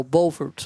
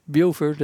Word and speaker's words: Beaufort: Well, the first thing was we got off the train at what they call Beaufort, South Carolina. Everybody else says Beaufort. It's spelled the same way.